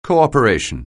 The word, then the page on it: cooperation: anglais